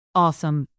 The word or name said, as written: awesome